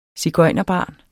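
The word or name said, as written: sigøjnerbarn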